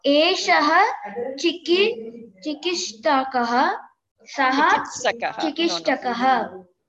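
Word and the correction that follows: चिकित्सकः: No. You're mispronouncing it. You're mispronouncing it, actually. Say it again. Eshaha chikitsakaha. He's a doctor.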